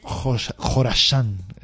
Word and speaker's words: Khorasán: También he pronunciado Khorasán, KH.